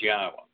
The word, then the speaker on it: Yahweh: Yahweh, that there are three destinations for human souls.